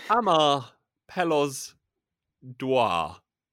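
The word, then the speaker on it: Amar pelos dois: The winner, of course, is pronounced as Amar Pelos Dwar, which is exactly how Salvador himself would have said it.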